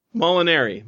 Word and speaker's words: Molinari: Right now, Molinari is, or how did you want it pronounced? – Molinari.